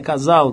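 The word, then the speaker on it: Casale: Morreu no, no dia 18 agora passado, o Bob Casal, né? Casal, né? Que, que é a pronúncia.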